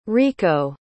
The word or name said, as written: rieko